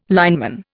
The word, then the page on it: lineman: Indian